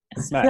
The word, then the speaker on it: Smaug: Oh, am I pronouncing it right? Am I saying that right? – Smaug. – Yeah.